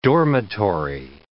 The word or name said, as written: dormitory